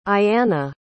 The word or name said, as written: ayiana